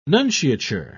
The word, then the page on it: nunciature: British English